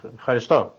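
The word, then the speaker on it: ευχαριστώ: A ver si lo digo bien, Efjaristó. – Efjaristó, muy bien, sí.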